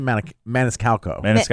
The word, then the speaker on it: Maniscalco: Sebastian Maniscalco.